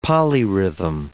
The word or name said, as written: polyrhythm